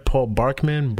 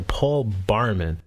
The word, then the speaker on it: Barman: Alright, does Paul Barman, am I saying it right? Paul Barkman? Barman? Okay, Paul Barman.